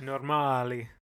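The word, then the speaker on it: Normaali: Am I hitting that right? Normali? – Normali. – What's your source on that? – I put it into Google Translate and asked them to pronounce it for me. – Okay. – In Finnish. It means normal in Finnish.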